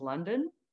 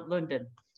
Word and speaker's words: Lunden: Actually, it's you pronounced it correctly, but I usually go by the Canadianized version of it, which is London. – London. – But in Okay. – Norwegian, it's more lunden.